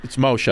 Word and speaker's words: Moshe: and we both love and respect Moshe Kasher. – Uh-huh. – Paul sends me a text once. He goes, yo, he goes, you ever seen this kid Moses Kasher? It's Moshe.